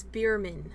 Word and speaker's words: Beiermann: It's Bierman. – Yes. – Okay. – B-E-I-E-R-M-A-N-N.